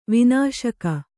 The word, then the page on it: ವನಶಿಖಿ: vana śikhi